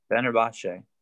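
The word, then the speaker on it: Fenerbahçe: He's famed Arsenal player is going to Fenerbahce. or whatever, how you say it? – Fenerbahce.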